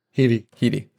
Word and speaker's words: Headey: How do you pronounce her name? Sorry, Lena Headey. – Headey.